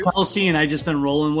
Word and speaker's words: Kelce: We've all been saying his name wrong, apparently. They had Travis on the thing. He said, my last name is Kelce.